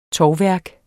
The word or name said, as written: tovværk